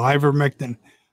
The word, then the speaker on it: Ivermectin: Ivermectin? – There you go. Ivermectin.